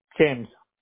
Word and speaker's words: James: James. – How do you spell that? – J-A-M-E-S. – D-E-A-M-S? – J.